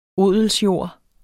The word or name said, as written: odelsjord